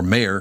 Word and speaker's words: Maher: We had Matthew Maher, or Mayer. Excuse me. I called him Maher again. Thank God you told me to say Mayer because that's how he pronounces it. It's M-A-H-R.